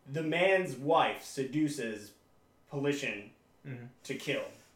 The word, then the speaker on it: Politian: But no one knew how to pronounce it correctly. – Did they call it Politico? – They called it Politician a lot. – Politician. – And I was like, you're adding, like, an extra syllable and three extra letters in there.